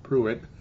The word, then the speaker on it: Pruitt: Is it pronounced Michael Pruitt? Michael Pruitt, tight end? – Michael Pruitt.